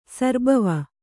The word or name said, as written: ಸರ್ಬವ